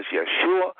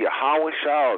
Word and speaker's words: Yeshua: You're going to hear a lot of Jesus tonight, but please know that there was no J in the Hebrew and that his name was Yeshua. Many pronounce it Yahawashah or variations of that name.